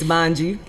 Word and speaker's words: Mangyee: My name is spelled funny just ahead of time. So it's Manji, like you said. My Instagram is at Manji, M-A-N-G-Y-E-E underscore R-E-V-E-R-I-E. That's Manji Reverie.